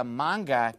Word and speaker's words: manga: I don't wear some long garment down to my ankles so that I can look like a manga character.